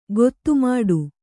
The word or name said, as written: ಖಾತೆಮಾಡು